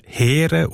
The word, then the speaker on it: Heer: Das Wort spricht man im alten Schweizerdeutsch mit Längum E als Heer aus.